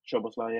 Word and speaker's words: Szoboszlai: So we got McAllister, Szoboszlai. Is that how you say his name? – Szoboszlai, yeah.